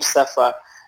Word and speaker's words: Sefa: Okay, I am, as you call the name, Sefa is the name Sefa, S-E-F-A, that's how it's spelled.